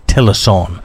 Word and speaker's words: Tillotson: And people say Tillison is actually Tillison. It's pronounced T-I-L-L-O-T-S-O-N.